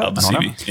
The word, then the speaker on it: Aldosivi: who was on loan at Argentina's Aldosivi, I think is how you say that. – Aldosivi, yep.